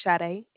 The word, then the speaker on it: Sade: Okay, my name is Sade, and it's spelled S-A-D-E. – Say that one more time. – Sade. – Spell it. – S-A-D-E.